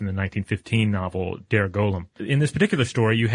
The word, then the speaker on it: golem: It can also, since this is a Jewish idea, it can mm-hmm. be pronounced goylem, mm-hmm. – Which is, I guess, more in keeping with the Hebrew.